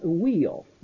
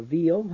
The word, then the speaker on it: Weil: And that's Simone Weil, or Veil, however you wish to pronounce it, W-E-I-L.